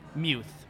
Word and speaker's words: Muth: Max Muth, and I also had meatless pizza. – Nice. Perfect. Did you say your last name's Muth? – Muth. – Muth. – M-U-T-H.